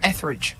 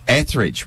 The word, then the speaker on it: Etheridge: Etheridge. – Etheridge.